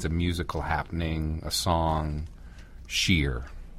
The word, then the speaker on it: shiyr: Sheer. – Okay. – People see it and they think Shire because of the S-H-I-Y-R.